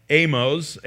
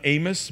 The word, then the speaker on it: Amoz: We don't really know a lot about him except that he's the son of Amoz, not Amos, but a different person than what we read from our prophetic book of the book of Amos. So it's Amoz or Amoz, however you would like to pronounce that.